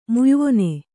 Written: ಮುಯ್ವೊನೆ

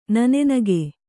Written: ನನ್ನಿಗ

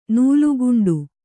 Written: ನೀಲಕಂಠ

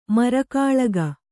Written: ಮರಕಾಳಗ

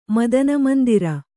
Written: ಮದನಮಂದಿರ

